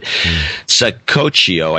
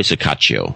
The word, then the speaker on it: Saccoccio: Saccoccio, I think. It's S-A-C-C-O-C-C-I-O, which I think is Saccoccio or Saccaccio. Probably Saccaccio. That sounds right.